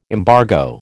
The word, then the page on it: embargo: American English